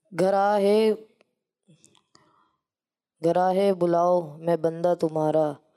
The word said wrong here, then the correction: گھرا: دیکھیں آپ نے الفاظ تو ادا صحیح کیے, تلفظ صحیح نہیں ہے. گھرا ہے بلاؤں میں بندہ تمہارا, مدد کے لیے آؤ یا غوث اعظم.